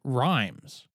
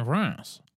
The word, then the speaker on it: Reims: The city of Reims might sound confusing to you, but that's because Reims is one of the weirder bits of French pronunciation out there. It's spelled R-E-I-M-S, and as an American, I had always pronounced it Rhymes until I learned better.